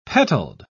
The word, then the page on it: petaled: British English